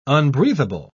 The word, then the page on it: unbreathable: British English